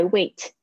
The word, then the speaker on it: weigh: 哦，好难念哦，weigh weigh 就是指称重，因为我们说 by weight 这个后面有加一个 t，这个是名词，那它的动词呢，就是 weigh，是不是好像有点像是叫人 weigh 啊？weigh，所以是 weigh，注意一下，它是 a 的发音，那个 gh 没有发音哦。